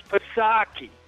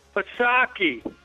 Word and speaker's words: Psaki: Jesse, I'm sick of you radio guys saying it wrong. It's Psaki. You got it? You know who I'm talking about? That redheaded... Oh, God, she's homely. Psaki. Say it right. Psaki.